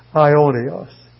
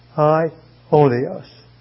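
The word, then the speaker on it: Ionios (αἰώνιος): Hear the word Ionios. A-I-O-N-I-O-S. Ionios.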